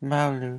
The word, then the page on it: maulu: Lwaitel